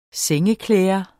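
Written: sengeklæder

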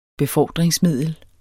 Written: BEFORDRINGSMIDLER

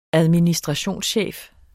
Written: administrationschef

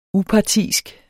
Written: upartisk

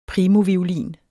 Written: primoviolin

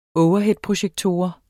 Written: overheadprojektor

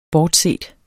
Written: bortset

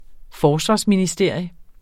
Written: Forsvarsministerium

